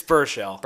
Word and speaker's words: Baruchel: It's Baruchel. – Baruchel? – Yes. – Like Bushel? Baruchel.